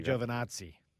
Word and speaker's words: Giovinazzi: Let me pronounce that correctly for you. – Thank you, Giovinazzi, or the Italian Jesus.